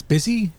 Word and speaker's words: Busy: And Karen requested it, and it's it's busy.